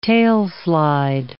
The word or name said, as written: tailslide